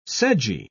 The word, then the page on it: sedgy: British English